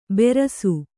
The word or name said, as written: ಬೆರಸು